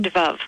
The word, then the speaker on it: devove: I pronounce it devove. – Devove. – Yeah, it was a cross between divine. It's just taking divine and love, putting them together.